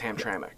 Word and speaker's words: Hamtramck: I said Hamtrak. – Yeah, you did. – I did, and it's Hamtramck.